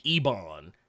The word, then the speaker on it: Ebon: Because his name is spelled E-B-O-N. – Okay. – Which, under normal circumstances, is Ebon. Which, to me, sounds infinite. – Now you're being nasty. Nobody says Ebon, motherfucker. That made my skin is wrong. Well, because Ebony. That, like, you've heard Ebon before. It's honestly a more proper way to say it, but, you know, because...